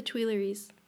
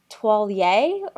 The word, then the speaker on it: Tuileries: It's the Tuileries. – That's not how you say it. It's like. – That's how I say it. It's like Toilier or something.